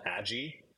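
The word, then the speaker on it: Adjy: Well, I mean, one of my favorite albums from the last couple of years is this band called Adji, who I only recently figured out that it's pronounced that way. It's A-D-J-Y.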